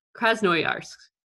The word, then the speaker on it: Krasnoyarsk: Krasnoyarsk. I definitely said that wrong. Krasnoyarsk. There we go. That sounds right.